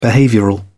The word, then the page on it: behavioural: British English